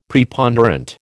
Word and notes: Preponderant: American English

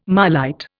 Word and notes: marlite: Indian

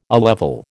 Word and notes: A level: American English

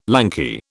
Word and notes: lanky: British English

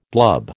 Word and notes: blub: American English

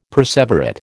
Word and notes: perseverate: American English

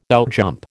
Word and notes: outjump: American English

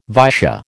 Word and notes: Vaisya: American English

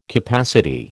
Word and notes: capacity: American English

